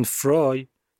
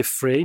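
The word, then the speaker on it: Frey: گلن فرای گول اسپل اسمشو نخوریم شبیه فری نوشته میشه اما تلفظ درستش فرایه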